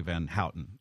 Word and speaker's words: Van Houten: You spend a great deal of time in your new book, Role Models, talking about Leslie Van Houten.